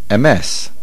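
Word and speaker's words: MS (אמת): The stress is on the mem, MS.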